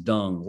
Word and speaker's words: Deng: I think it's pronounced Dung, Luol Deng.